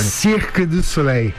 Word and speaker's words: Cirque du Soleil: Por favor, como é que, qual que é a pronúncia correta em francês, você que esteve lá durante muito tempo? Fala pertinho do microfone. – Cirque du Soleil. – Ah, eu falei certo.